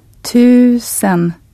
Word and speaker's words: tusen: The word for thousand with a long Swedish U sound. – Tusen.